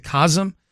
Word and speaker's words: COSM: I guess they pronounce it COSM. COSM is how it's spelled.